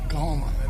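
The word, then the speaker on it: Coahoma: Is that pronounced? Did I pronounce that right? Is it Cahoma County? – Cahoma. – Yeah, in Cahoma County.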